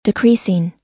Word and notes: decreasing: long-i

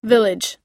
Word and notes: village: i

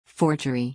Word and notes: forgery: or

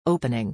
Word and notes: opening: i

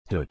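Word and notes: hood: /húd/

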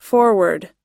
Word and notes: foreword: or

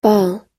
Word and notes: ball: American English

